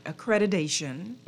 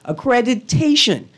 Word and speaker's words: Accreditation: Accreditation and Equivalency Council of the Bahamas. – All right. Is it okay if I mess up the T with a D? – No. – No, I have to say it properly.